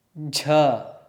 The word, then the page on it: jh: sanskrit